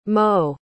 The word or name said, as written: mots